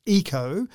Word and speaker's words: Yiko: And its name is Eco, spelled Y-I-K-O, but pronounced Eco.